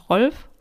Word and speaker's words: Rolf: Und bevor ich euch jetzt den Rest der Familie vorstelle, muss ich kurz dazu sagen, dass der Familienvater Rolf heißt und ich werde ihn auch deutsch aussprechen, weil er auch ursprünglich aus Deutschland kommt.